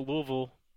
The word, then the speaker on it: Louisville: I believe it's pronounced Louisville. I'm just being a jerk about it.